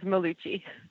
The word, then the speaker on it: Malucci: How do you said it exactly right. It is Malucci.